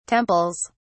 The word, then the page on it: temples: British English